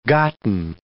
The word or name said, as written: gotten